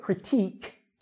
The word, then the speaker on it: critique: When we look at critique, it's a long sound, right?